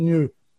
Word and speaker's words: gnu: And in the 60s, there was a few songs written about the GNU, because of the English dictionary says that GNU is supposed to be pronounced new.